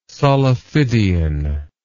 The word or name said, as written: solifidian